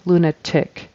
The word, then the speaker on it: lunatic: And lunatic, it should be ih, lunatic. And the syllable stress is on the first syllable. Lunatic, lunatic.